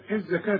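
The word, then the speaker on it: الزكاة: واتوا الزكاه، الزكاه تكتب بالواو وتنطق بالالف. واتوا الزكاه وما مرققه تقدموا.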